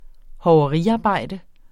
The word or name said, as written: hoveriarbejde